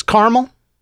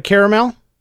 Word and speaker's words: caramel: She says caramel instead of caramel. It's obviously spelled caramel, C-A-R-A-M-E-L, caramel. It's not spelled C-A-R-M-E-L.